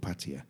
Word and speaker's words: Opatija: Oh, and something which caught us out is that the J is spelled R-I-J-E-K-A. The J is spelled, is pronounced E, so Rijeka. Same with Opatija. O is O-P-A-T-I-J-A, and we were calling it Opatija and Rijeka when we first got there, and we looked like complete idiots. And you'll find that as well with Macedonia and all, and a lot of those countries around there.